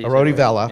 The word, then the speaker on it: Arodi Vela: Yeah, Arodi Vela.